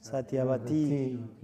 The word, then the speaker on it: Satyavati: Satyavati. Long I.